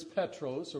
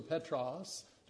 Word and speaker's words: Petros: But the word Peter is Petros or Petros, depending on how you'd like to pronounce that.